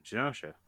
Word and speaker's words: Genosha: How do you pronounce that? – Easy, Genosha.